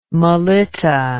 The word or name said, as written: melitta